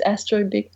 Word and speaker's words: Astrobic: And my friend said, why don't you make your name Astrobic like Astro Boy? But it's Astrobic.